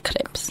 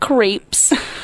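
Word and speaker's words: crêpes: Crepes. – Crepes. – Yeah. – Crepes. – No, crepes, not creeps.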